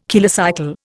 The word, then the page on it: kilocycle: Indian